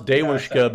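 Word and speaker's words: Devushka: Donbass Dewushka, because that's how she pronounces it. It's like Daewoo from you know, the Korean company.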